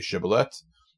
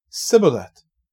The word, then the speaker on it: Shibboleth: If he said, "No," then he would say to him, "Please say Shibboleth." And he would say, "Sibboleth," for he was unable to pronounce it right.